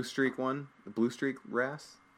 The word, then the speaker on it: wrasse: Yeah, so the next one then is cleaner fish, or the cleaner wrasse.